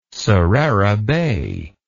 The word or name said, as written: sarera bay